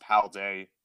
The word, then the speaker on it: Paldea: like the Paladian or something like that, right? – I think it's pronounced Paldea. – So, yeah, so the Paldean region.